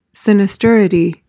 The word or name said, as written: sinisterity